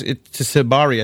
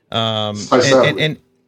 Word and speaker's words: Tysabri: And then, you know, this is that, it's Tysabri, I think it's called the drug, right? – Tysabri. – Tysabri.